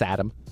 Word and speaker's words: Saddam: It's pronounced Saddam. – Saddam Hussein. – Yeah, Saddam.